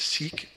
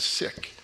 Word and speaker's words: Sikh: It is the Sikh tradition, and I think most of us maybe are familiar with Sikh as the pronunciation, but the correct pronunciation is Sikh.